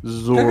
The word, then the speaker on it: Zaron: It's, you pronounce it like Sauron, but with the Z. Zoron.